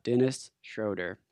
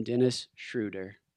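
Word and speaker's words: Dennis Schröder: I know I just said his name incorrectly, but this man deserves a proper pronunciation of his name. Tonight, we will call him Dennis Schroeder.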